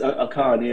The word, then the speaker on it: Akan: I want to say Akan people, Akan, but I'm not sure if that's quite correct. – No, it is Akan, yeah.